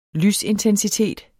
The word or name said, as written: lysintensitet